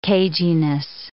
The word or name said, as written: cageyness